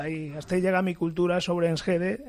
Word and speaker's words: Enschede: Enschede.